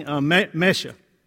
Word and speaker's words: Mesha: In fact, there's a story that is told about the Moabite king, Mesha. Kind of rhymes with Kesha.